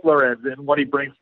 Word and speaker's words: Lorenz: I know the last name is pronounced Lorenz, but yeah, I think it's I think it's Rieger Lorenz.